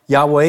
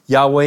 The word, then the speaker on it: Yahweh: Yahweh is hypothesized to be the pronunciation of YHWH.